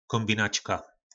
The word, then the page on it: kombinacka: po našimu